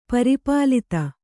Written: ಪರಿಪ್ಲುತ

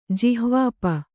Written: ಜಿಹ್ವಾಪ

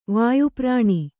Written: ವಾಯುಪುರಾಣ

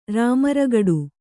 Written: ರಾಮರಗಡು

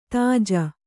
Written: ತೇಜಿ

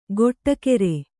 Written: ಗೊಟ್ಟಕೆರೆ

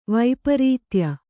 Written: ವೈಪರೀತ್ಯ

